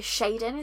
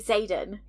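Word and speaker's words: Xaden: So Shaden is how she was saying it, and I was like, no, it's Xdan. And then Xdan. – And then on the audio they say Zayden.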